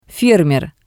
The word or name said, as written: fermer